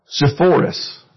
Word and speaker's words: Sepphoris: Sepphoris.